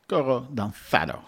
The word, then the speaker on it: Coro Danzado: Coro Danzado, zo moet je dat uitspreken.